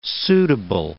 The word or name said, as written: suitable